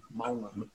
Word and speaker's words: Mo'unga: Maunga. – Richie Mo'unga. Yeah, Mo'unga. Huh? – Tongan? – Yeah. – Who? Yeah. So Mo'unga. So there's that apostrophe between the O and the U, Mo'unga.